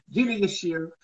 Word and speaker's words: Yashere: Gina Yashere.